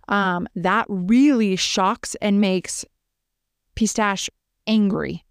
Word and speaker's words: Pistache: And her daughter, Pistache, which is like pistachio or pistachio. Is the actual way you pronounce that pistachio? – Pistache.